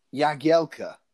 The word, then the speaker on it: Jagielka: Yeah, well, and it's like Jagielka. That's how you're meant to pronounce it. Because he came out and said, yeah, that's how call me this.